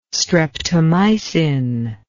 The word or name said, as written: streptomycin